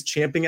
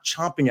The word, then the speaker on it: champing: By the way, it is champing at the bit, not chomping at the bit. Tuck that away. Take it with you. The proper way is champing. That's also how it's spelled.